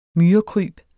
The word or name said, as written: myrekryb